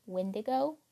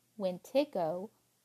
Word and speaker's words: Wendigo: There are many different ways that it is pronounced, anything from Wendigo, Wentigo, Witico, and Weetigo.